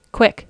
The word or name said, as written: quick